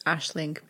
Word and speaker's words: Aisling: I've been calling her Aisling. – It's Aisling. – Aisling. – I mean... – Like a little ash.